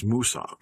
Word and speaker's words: Mwsog: It is spelled M-W-S-O-G, which translates to English in moss. But if you say it in Welsh, it is Moosog.